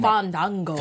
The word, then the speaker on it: Fandango: First of all, say the name right. – That happens. – Make sure you say his name Fandango. – Right. Fandango.